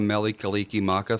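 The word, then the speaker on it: Mele Kalikimaka: Interesting about the Melekeleke Maka thing? – What? – Is it's not actually in Hawaiian. It's just how you pronounce Merry Christmas using the Hawaiian alphabet. – Meli, they don't have an R.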